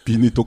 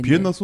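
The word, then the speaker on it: Wien: 전 어렸을 때 빈도 몰랐고 비엔나도 몰랐고 나이 먹어서 빈이 똑같은 걸 알았죠. – 빈 저기 비엔나는 이제 미국식 발음이고 영어식 발음이고 빈은 이제 보통 원인적인데 비엔나 소세지 그 할 때부터 알았는데.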